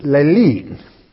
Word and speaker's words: lalein: L-A-L-E-I-N. Now, which is lelin. And remember the final spelling of this. E-I-N.